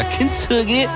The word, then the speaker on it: Kintsugi: Rebecca, Elisa con Kintsugi. – Avete notato la pronuncia proprio stretta, nipponica, Kintsugi.